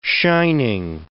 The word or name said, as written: shining